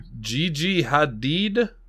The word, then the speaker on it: Gigi Hadid: I don't know how to pronounce this name. Boy. Gigi Hadid? – Yeah, you got it.